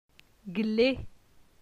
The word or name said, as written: Gle